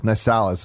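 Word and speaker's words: Nicalis: Now, is this Nisalis? Nick Alice? How would you pronounce it? – Nisalis is Nisalis.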